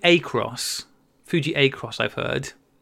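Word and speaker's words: Acros: See, I say Fuji Akros. Is that how you say it, Rach? – It is, but maybe that's your influence.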